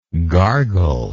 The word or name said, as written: gargler